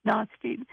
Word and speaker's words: Nostradamus: Pronounce his name for me. Nostradamus. as Brooklyn would call him, Nostradamus.